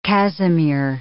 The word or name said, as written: cassimere